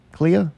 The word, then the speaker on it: Clea: How do you say this one's name? Clea? C-L-E-A? Clea? – Miss Cleo. – It's Clea, yeah.